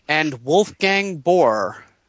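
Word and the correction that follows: Baur: Oh, Bauer, yeah. – Bauer, okay.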